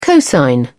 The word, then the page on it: cosine: British English